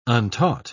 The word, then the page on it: untaught: British English